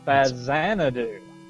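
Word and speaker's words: Faxanadu: Turns out it's pronounced Fazanadu. It is a Z. It is the X is pronounced like a Z."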